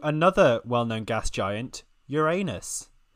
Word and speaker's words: Uranus: So uh, and I believe the pronunciation now is is accepted as Uranus, but it is uh, a very large gas giant uh, planet and also very icy.